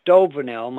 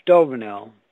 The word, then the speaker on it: Stouvenel: And once again, my name is David Stovenel. My last name, I'll spell it because they'll want specifics on this. S-T-O-U-V-E-N-E-L. David Stovenel, case number 01031.